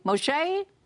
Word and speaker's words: Moshe: Moshe, Moshe, that's how you pronounce it.